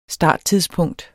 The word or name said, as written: starttidspunkt